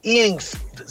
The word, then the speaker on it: INXS: Mira, Fernando, hay un grupo que se llama INX. Se escribe INXS.